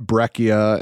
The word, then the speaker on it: breccia: Yeah, one of my favorite rocks is a rock called breccia, and it's B-R-E-C-C-I-A, so it's a very mispronounced word. A lot of my students will call it breccia, and I'm like, no, it's like you breccia, with a ch.